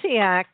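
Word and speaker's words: Essiac: Now, I can't go on until I ask you about what Essayac, how do you pronounce it? – Essayac.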